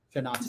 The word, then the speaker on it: Shana Tova: Shana Tova. – Celebrates. Did I say it right? – Yes, you did. It was perfect.